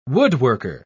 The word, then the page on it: woodworker: British English